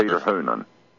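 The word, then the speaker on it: Hounam: Is that how you pronounce his name? – Yes, Hoonan? – Peter Hoonan.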